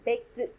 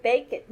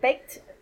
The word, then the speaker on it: baked: It's like when you're studying English, they said like when you have the ED termination in a word, it sounds like it, like baked. I don't know, it's a hard pronunciation for us Hispanic people. So I say bake it, meat, bake it. But it's, for me, it's like baked.